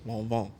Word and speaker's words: Lanvin: Hey, there's a Pusha T song about this, and it's pronounced Lanvin.